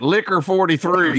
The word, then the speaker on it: Licor: It's just spelled LICOR. – Yeah. – I tried to spell it with a Q and was not getting the result that I wanted. – And it might be about like Liqueur 43. We might be pronouncing it slightly incorrectly with our. – Well, in West Virginia, we say it Liquor 43.